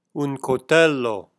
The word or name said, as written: coltello